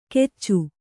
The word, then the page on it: ಕೆಚ್ಚು: keccu